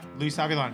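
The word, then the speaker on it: Luis Avilán: The man comes around, which uh, this is uh, Luis Avilan. Is that how you pronounce it? Oh yeah, Luis Avilan.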